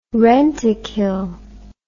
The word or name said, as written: rentokil